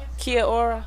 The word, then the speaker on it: Kia ora: Kia ora.